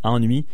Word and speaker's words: Ennui: C'est la façon qu'il faut le prononcer, mais ça s'écrit comme on et oui en anglais. Donc ennui.